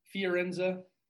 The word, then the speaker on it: Fiorenza: It's Fiorenza.